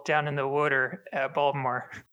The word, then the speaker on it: Baltimore: Baltimore, as we call it. – Is that... How do I say it? – Balmer. – Balmer.